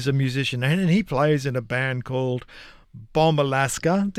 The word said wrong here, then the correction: Bomba Alaska: Did I pronounce that correctly? Is it Bombay? Yeah. Bombay? – Bomba Alaska. – A bomba. It's a bomba.